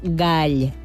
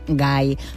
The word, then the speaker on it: gall: I s'ha de pronunciar gall i no gai, quan fa referència a l'au de cresta carnosa i vermella, una paraula, per cert, molt polisèmica.